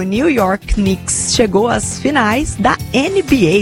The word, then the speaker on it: Knicks: Rafa, tô atualizando aqui o nosso feed, a gente volta a falar de esporte, porque Knicks e Seleção Brasileira, eu falei certo? Knicks? – Falou certinho. – É assim que pronuncia? – Falou certinho.